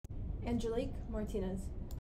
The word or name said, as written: Angelique Martinez